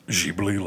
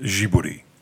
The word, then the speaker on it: Ghibli: I like the way that you don't pronounce it Ghibli like a normal American. You pronounce it like a Japanese person. You pronounce it Ghibli.